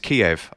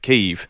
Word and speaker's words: Kyiv: And obviously, also, I always pronounced it as Kiev. I think most of us have, and that's the Russian pronunciation of the town. And now we all know to call it Kyiv, which is, you know, something we're picking up.